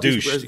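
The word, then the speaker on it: Deutch: But Dush had an interesting quote. – I think it's pronounced douche. You keep on mispronouncing. It's douche, Sean. Douche.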